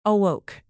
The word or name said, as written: awoke